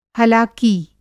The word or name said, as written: हलाकी